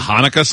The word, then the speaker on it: Hanukkah: I don't know how do you say it? – The Hanukkah song? – Oh, is that what it is? – Oh, yeah.